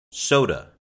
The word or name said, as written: soda